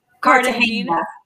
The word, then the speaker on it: Cartagena: Um, I can't pronounce this, but eight is the cart Cartagena.